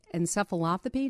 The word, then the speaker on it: encephalopathy: So we've been hearing more and more over the last few years about CTE, chronic traumatic encephalopathy. Did I say that right? – That's right, yeah.